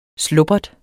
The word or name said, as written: slubbert